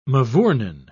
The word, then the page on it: mavournin: British English